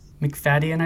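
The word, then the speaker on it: Macfadyen: McFadden, how do you pronounce his name? – McFadden, I believe, yes.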